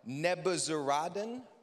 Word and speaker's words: Nebuzaradan: Nebuzaradan, which literally translated to the chief slaughterer.